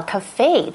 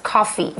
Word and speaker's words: cafe: Okay, 那我们其中还看到 cafe 这个字。Okay, notice this word is not pronounced coffee. 我不知道为什么大家都会把 cafe 念错。 – Because it's a French word, right? – 因为它是个法文字。那它是 coffee, 其实是咖啡，但拼法完全不同，是 C-O-F-F-E-E.